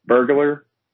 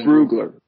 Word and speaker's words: Brugler: Am I pronouncing Dan's last name wrong? Am I doing that? – Dan who? – Burglar? No, not Burglar. – Oh, Brugler. Brugler. Dan Brugler.